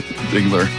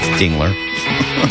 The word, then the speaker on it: Dingler: And you want to make sure you say it right. – Dingler. – Dingler. Get that right.